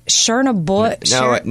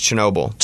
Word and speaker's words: Chernobyl: The Chernobyl. – No, it's Chernobyl.